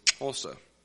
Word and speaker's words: Xhosa: And the language that we are going to learn during language school is Xhosa. It's spelled X-H-O-S-A. The X makes a sound. There are three distinct clicks.